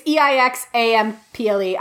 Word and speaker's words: Eixample: How does Spanish pronounce the X? Does anyone know? – I assume it's Catalan. It's Catalan, so. – Oh, that's true. It is Catalan. Balls. Anyway, I'm sorry. It's Eixample.